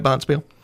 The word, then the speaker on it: Bonspiel: Am I saying that right, Bonspiel? – Correct.